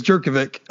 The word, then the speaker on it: Jurkovic: So it is Jurkovic for life. I only pronounced it correctly, like just so Jude didn't have a heart attack.